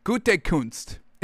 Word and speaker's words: Gutekunst: If I was the Packers general manager, Brian Gutekunst. It's like Gutentag, but Gutekunst. Gutekunst.